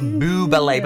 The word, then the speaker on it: Bublé: No, it's Michael Bublé. But they're like, no, but it's spelled Bubble as in one B. It's B-U-B-L-E. And then see that it's got a little thing on the top. – It's Bublé. Michael Bublé.